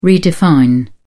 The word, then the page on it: redefine: British English